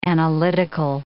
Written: analytical

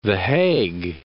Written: Hague, The

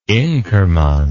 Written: inkerman

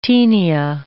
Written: taenia

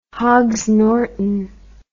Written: Hogsnorton

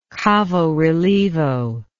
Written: cavorelievo

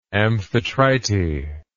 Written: amphitrite

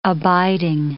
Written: abiding

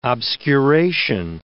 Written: obscuration

